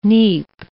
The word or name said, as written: neap